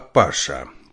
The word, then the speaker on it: Паша: а Паша», — прервал Алексей Герман Владимирович. «Правильнее с ударением на второй слог.